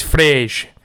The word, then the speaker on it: Fred: He's Brazilian. He's short. It's Frej. I'll start off with Fred.